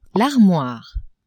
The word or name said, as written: armoire